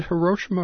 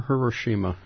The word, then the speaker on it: Hiroshima: Is it Hiroshima or Hiroshima? – The Japanese pronunciation is Hiroshima, and the American one is more of a Hiroshima, as okay. – You've been saying.